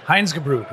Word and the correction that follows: Reinheitsgebot: Heinz Gebrut. How do you say that again? – Reinheitsgebot.